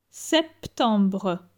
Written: septembre

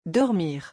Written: dormir

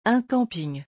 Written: un camping

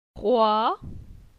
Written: Croi

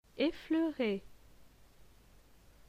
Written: Effleurer

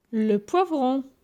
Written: Le poivron

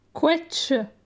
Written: Quetsche

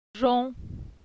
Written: Jon

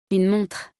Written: Une montre

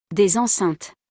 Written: Des enceintes

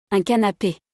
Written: Un canape

